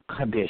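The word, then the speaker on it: kadesh (קדש): That's Q-A with the long vowel sound up over the A, D-E-S-H, with the what? With the long, short vowel sound over the E. So it would be pronounced Kadesh, Kadesh, Kadesh.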